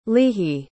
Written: leehi

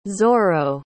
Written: zorro

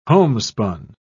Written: homespun

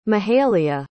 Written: mahalia

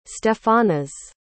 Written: stephanas

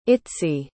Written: itsy

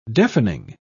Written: deafening

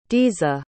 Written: diza

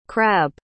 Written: crabbe

